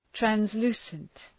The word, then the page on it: translucent: trænz’lu:sənt; træns’lu:sənt